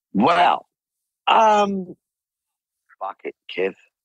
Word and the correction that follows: Phuket: I'm pronouncing it wrong. Phuket. – Oh, Phuket, yes.